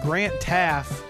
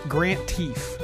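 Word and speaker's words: Grant Teaff: I mean, it's stuff that I'm sure you knew, but I didn't send it to you because the narrator called Grant Taff Grant Teef. That's how he pronounced Grant Taff.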